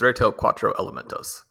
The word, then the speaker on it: Reto Cuatro Elementos: I always have a little trouble pronouncing it myself, but it's Reto Cuatro Elementos.